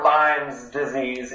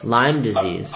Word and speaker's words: Lyme disease: Is it Lyme disease or Lyme's disease? Is it a silent S or is it a real S? Come on. – I'm pretty sure it's no S.